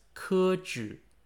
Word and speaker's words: 科举: Keju, pronounced in Chinese as Keju, means subject recommendation, originating from the use of testing people on various subjects to recommend them to office.